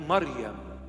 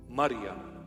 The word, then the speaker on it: Maryam: Or in the proper pronunciation, in Hebrew or Syriac or Aramaic, Maryam. Wow. It's so sweet when I call her Maryam. That's the correct pronunciation of her holy name, Maryam.